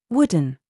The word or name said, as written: WE